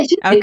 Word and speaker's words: Judit: Am I saying your name right? Judit? – Yeah, Judit.